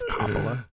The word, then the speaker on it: Coppola: It's Coppola.